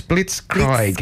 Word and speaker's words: Blitzkrieg: We've got, is it Blitzkrieg or Blitzkrieg? – I can never pronounce that. – I think it's, let me see here. Let me see a little bit. So it's Blitzkrieg.